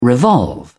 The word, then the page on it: revolve: British English